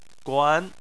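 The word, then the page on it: guan: tone 2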